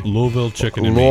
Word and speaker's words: Louisville: I was in, and you have to get this pronunciation right, I was in Louisville, Kentucky. Louisville, Kentucky, mm-hmm.